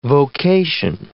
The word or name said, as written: vocation